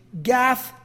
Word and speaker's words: Gath: Gath is a word in Hebrew. It sounds like the word for tell. So if you were listening to this in Hebrew, it would sound like something like this: Gath, not in Gath.